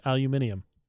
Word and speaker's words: aluminium: That's how it's said in English. Aluminium.